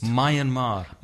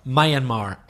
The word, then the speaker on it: Myanmar: Is it Myanmar? Myanmar? How is that pronounced? – Myanmar. – Myanmar. M-Y-A-N-M-A-R.